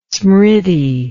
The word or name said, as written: smriti